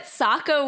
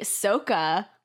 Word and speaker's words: Sokka: And then another fun fact is apparently Sokka with the long O is the Asian pronunciation of the name, which is what they use in the Last Airbender movie.